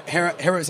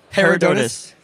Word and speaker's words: Herodotus: How did you guys come up with the need for Herodotus? How do you pronounce it? – Herodotus.